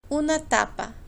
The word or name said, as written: una tapa